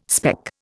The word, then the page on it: speck: Indian